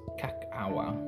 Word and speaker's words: kakawa: Cacao, pronounced by the Olmecs as cacawa. Cacawa, K-A-K-A-W-A, dates to 1000 BC or earlier.